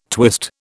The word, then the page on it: twist: British English